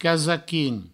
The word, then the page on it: casaquin: provençal